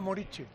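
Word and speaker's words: Muriqi: El jugador este del Mallorca, Muriqui, no sé, sí, se escribe Muriqui, ¿no? – Y se pronuncia Murici. – Se pronuncia Murici.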